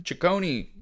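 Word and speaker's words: Taccone: So Andy Samberg, Akiva Schaffer, and Yorma, I think it's Ciccone? – Ciccone. Yorma Ciccone. Yeah.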